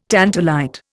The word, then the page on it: tantalite: Indian